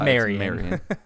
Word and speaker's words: Marion: So this one's out of Marion County, Florida. I think that's how you pronounce it. It's Marion.